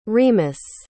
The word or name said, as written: remus